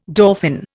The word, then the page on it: dauphin: Indian